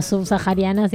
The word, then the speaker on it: subsahariana: No, pero subsahariana sí. – ¿Sí? Subsahariana. Es una H muda, loca. Amiga, acá en español la H muda.